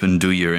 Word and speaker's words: nduja: Like when they slip nduja into something, I'm a sucker for that. – I'm impressed by the pronunciation of nduja, because that trips a lot of people up.